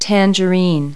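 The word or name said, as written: tangerine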